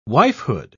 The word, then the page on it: wifehood: British English